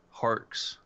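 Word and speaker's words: Harkes: I was I was referring to the pronunciation guide here. It's Harks. Thank you, sir.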